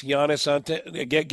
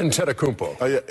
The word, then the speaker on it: Giannis Antetokounmpo: Giannis Antetokounmpo. – And you're leaving a syllable out, but it's a good effort. – Oh, the Greek freak. – Antetokounmpo.